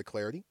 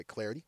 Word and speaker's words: Clardy: Follow me on Twitter at Troy Clardy, at Troy Clardy. Last name is spelled C-L-A-R-D-Y.